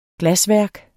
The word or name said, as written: glasværk